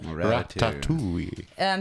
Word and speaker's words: Ratatouille: Ratatouille. How do you say that? – Ratatouille.